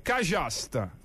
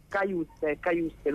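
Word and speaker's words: Cajuste: Io capisco, si dice Kajust in inglese, Kajuste dovrebbe essere. – Kajuste proprio? – Dovrebbe essere, sì.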